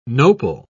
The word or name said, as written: nopal